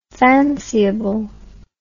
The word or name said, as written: fanciable